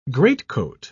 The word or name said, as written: greatcoat